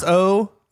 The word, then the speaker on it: SoGnar: SoGnar. – Can we talk about how dumb the English language is? Silent fucking letters. – Yeah. – Why? – Oh, the G.